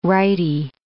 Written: righty

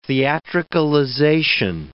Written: theatricalization

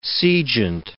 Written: sejant

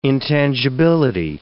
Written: intangibility

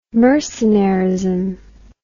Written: mercenarism

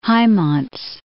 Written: Hymans

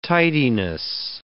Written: tidiness

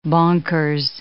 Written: bonkers

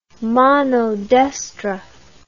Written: manodestra

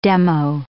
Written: demo

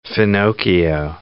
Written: finocchio

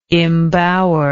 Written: imbower

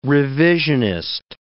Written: revisionist

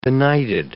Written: benighted

